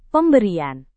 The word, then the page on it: pemberian: Indonesian